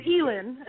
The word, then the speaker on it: Ehlen: It's Ehlen.